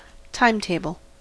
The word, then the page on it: timetable: American English